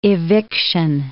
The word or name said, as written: eviction